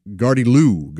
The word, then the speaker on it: gardyloo: The word this week, Gardiloo. That's right, Gardiloo. Actually, I think the emphasis is supposed to be on the end, the last syllable there. So Gardiloo, G-A-R-D-Y-L-O-O.